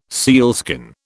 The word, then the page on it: sealskin: British English